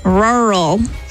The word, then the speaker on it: rural: Rural. I'm saying it right because it sounds like it sounds like your car trying to start when it's really cold.